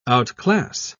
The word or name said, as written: outclass